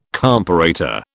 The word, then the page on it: comparator: British English